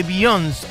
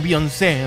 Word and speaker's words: Beyoncé: Hay discazo de Beyoncé, o Beyoncé en realidad, siempre aparece la policía de la pronunciación del nombre de Beyoncé o de Beyoncé y me dicen, no es Beyoncé, es Beyoncé.